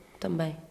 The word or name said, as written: também